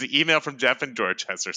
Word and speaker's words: Dorchester: So this email is from Jeff in Dorchester. Did I pronounce that right, Chris? – Yes, that would be correct.